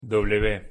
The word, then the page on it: w: Spanish